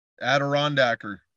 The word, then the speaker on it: Adirondacker: Is that how you pronounce that? – Adirondacker. Yeah, it's Adirondacks.